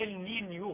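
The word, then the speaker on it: Niño: ظاهرة النينو، النينو، وربما تلفظ النينيو بالإسبانية، معناها الطفل في المهد.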